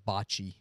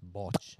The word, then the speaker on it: bocce: It's not pronounced bocce. It's botch.